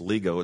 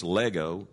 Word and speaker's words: lego: But there's two words here, and we've went over them before, and the one is lego, or I don't think it's lego, it's lego. It's with a long O, L-E-G-O, and it means really, this one means to lay forth, that is to relate.